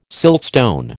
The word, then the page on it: siltstone: American English